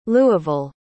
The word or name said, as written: louisville